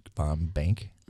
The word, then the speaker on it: VonBank: Right, we're back on the St. Paul Filmcast, and we have Kelly, how do we say it right? VonBank? – VonBank. – Yes.